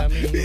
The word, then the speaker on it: Lamine: Lamine. Y digo yo que no. Son españoles, son nuestros conciudadanos, ¿verdad? ¿O qué pasa aquí? – Hombre, por favor, ¿cómo se puede decir que no? – Lamine ha nacido aquí, es español.